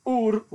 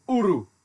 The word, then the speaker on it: Ur: Linguistically, it should be pronounced in Akkadian, which is the language that they spoke there. Now, hang on for this, my Akkadian's terrible. As Ur or Uru.